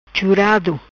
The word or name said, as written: Churado